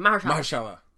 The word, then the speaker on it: Mahershala: Mahershala Ali. – Just say it as fast as you can. Mahershala. There you go.